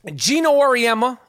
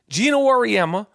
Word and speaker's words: Gino Auriemma: Gino Auriemma, I said his name right. See, I don't try to say his name wrong. Gino Auriemma, the coach of UConn women's basketball team that won 111 games straight, lost.